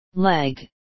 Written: leg

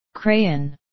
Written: crayon

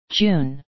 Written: june

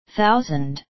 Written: thousand

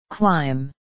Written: climb

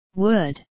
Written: wood

